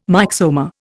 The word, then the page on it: myxoma: Indian